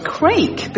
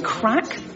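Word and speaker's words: craic: You know, actually, a lot of people think it's pronounced crake because of the spelling. But you ask any Irish person, and they'll tell you it's actually crack.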